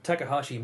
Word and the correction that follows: Takashi: Miike. – Yep, it's Takashi Miike. – Takashi? – Yep. – Oh, there's no H in that.